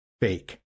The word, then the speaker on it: PHEIC: By the way, that's pronounced fake.